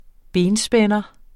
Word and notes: benspænder: -ˌsbεnʌ; -ˌsbεnˀʌ

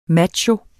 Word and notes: macho: [ ˈmadɕo ]; [ ˈmatjo ]; [ ˈmɑ- ]

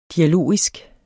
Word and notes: dialogisk: [ diaˈloˀisg ]

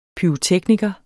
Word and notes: pyrotekniker: ˈpyːʁoˌtεgnigʌ; pyʁoˈtεgnigʌ

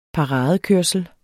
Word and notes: paradekørsel: dansk

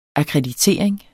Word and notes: akkreditering: akʁεdiˈteˀɐ̯eŋ; ɑkʁε-

